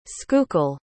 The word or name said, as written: schuylkill